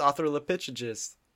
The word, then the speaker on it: Australopithecus: Teenage Mutant Australopithecus. I think, which actually probably starts with an A, and I also butchered. Is that it is Australopithecus. You're right.